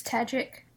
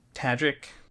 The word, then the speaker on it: Tajik: It's Tajik. – Tajik. – Is it? – Yeah, that was from the pronunciation guide. It rhymes with magic.